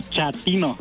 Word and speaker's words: Chatino: Chapino. – ¿Chapino? – No, chatino. – Es como chato, ¿verdad? Chatino. – Sí, azunero como chato, chatino.